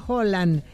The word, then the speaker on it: Haaland: Ya que hablan de pronunciación, Haaland se pronuncia Holland, pero ya todos le dicen Haaland, ya fuera de Escandinavia.